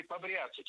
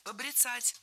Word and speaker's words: побряцать: Побряцать – неверно. Правильно, побрицать. Это слово имеет настолько непривычное ударение, что большинство людей не слышали и не представляют, что правильно – побрицать, брицать. Ударение в этом глаголе и в однокоренных глаголах ставится за корнем.